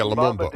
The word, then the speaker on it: Lumumba: She, and again, the police telling her this guy, I can't pronounce his name, Lumumba or something like that. – Yeah, Lumumba.